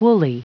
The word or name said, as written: woolly